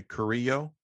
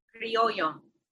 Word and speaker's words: criollo: She was, pronounce it for me, Carrillo? – Criollo.